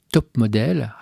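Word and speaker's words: Taupes Modèles: Elles forment un trio qui s'appellera les, les top modèle. Attention à la prononciation, hein, c'est top l'animal, hein, pas top modèle les mannequins.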